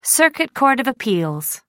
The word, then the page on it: circuit court of appeals: British English